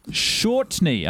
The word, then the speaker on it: Shortney: And also a huge hello to Shortney. I hope I pronounced that hello. – Correctly. – Yeah, you got it. You nailed it.